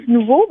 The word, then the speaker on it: Noovo: Puis nouveau, ça se prononce nouveau, mais ça s'écrit nouveau. N-O-O-V-O.